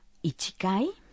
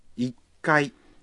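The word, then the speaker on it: ikai (一階): Uno es ichi, de modo que será ichikai. – No, la respuesta correcta es ikai. Ichikai es un poco difícil de pronunciar, por eso se dice ikai.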